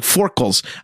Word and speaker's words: forecastle: It's spelled forecastle. The thing that they put them in the front of the ship where the water comes in. But the way they pronounce it, I could not catch it. I like even rewound. It's like forkles.